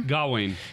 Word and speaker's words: Gawain: Gawain.